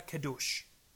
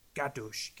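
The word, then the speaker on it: kadosh (קדוש): Det hebraiske ord for hellig, det er kadosh. Der står ikke kadosh en gang. Gadosh kan det også udtales.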